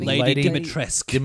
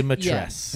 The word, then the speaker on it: Dimitrescu: Lady Dimitrescu. – Dimitrescu.